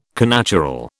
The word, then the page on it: connatural: British English